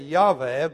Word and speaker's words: Yahweh: they took the vowels from the Hebrew word Adonai, which means Lord, and added those vowels to the tetragrammaton. And in Germany first, they began to say Yahweh, but since in German J is a Y, when we took it into English, we began to pronounce it as Jehovah.